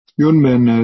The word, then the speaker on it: Yunmen: Yunmen, as some of you know, again I said, Yunmen is the Chinese way to pronounce his name. Umon is his name pronounced in the Japanese way.